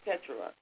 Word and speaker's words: Keturah: All of those ones that, I think her name is pronounced, I was calling her Keturah, but it's Keturah. And so Keturah was Abraham's third wife because, you know, he had Sarah, then he had Hagar, then he had Keturah.